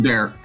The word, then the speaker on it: Bexar: Yeah, down here in Texas, it's pronounced Bexar.